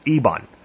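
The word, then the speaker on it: Iban: It's I-B-A-N, but it's pronounced Ebon.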